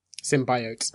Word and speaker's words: symbiotes: Symbiotes.